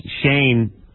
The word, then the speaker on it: Shane: Right, I said Shay. – Yeah, I'm sorry, it's Shane. – Shane? – Yeah, S-H-A-N-E. – Oh, isn't it funny? He says Shane, he sort of pronounces it like Shay.